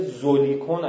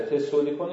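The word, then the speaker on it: Zollikon: سمینارهای ترجمه شده زولیکون اگر اشتباه نکنم، ولی سولیکونه، آلمانیش زد یه طور خاصی تلفظ میشه تو آلمان، تسه تلفظ میشه، یه شهره، تسولیکون یه شهره هایدگر افسرده بود، رفت اونجا به اینا فلسفه میگفت، مداد بسم هایدگر رو روان درمانی میکرد.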